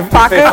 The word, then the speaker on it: Facu: Would you like Facu?